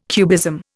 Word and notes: cubism: Indian

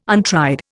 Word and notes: untried: Indian

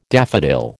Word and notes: daffodil: American English

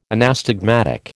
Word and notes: anastigmatic: American English

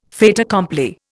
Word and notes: fait accompli: Indian

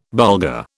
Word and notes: Bulgar: British English

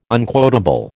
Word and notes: unquotable: American English

